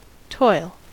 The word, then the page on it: toil: American English